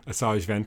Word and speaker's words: Asajj Ventress: Asajj Ventress.